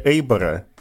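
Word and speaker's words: Eibar: Помимо того, что вы самый внимательный в Российской Федерации зритель бельгийского футбола, вы еще и основатель фан-клуба, внимание, испанского Эйбара. Или Эйбора. Кстати, сейчас сразу же поправит меня. – Ну, я не ругаюсь, кстати, за это. Вообще Эйбора, но сам факт, что люди произносят его название, меня уже радует.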